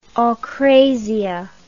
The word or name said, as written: akrasia